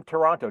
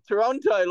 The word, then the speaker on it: Taranto: Tim Taranto. It's Taranto, I believe. – you laugh at my accent. Sounded like you said Toronto, like the city here. – No, I know. – Taranto, isn't it?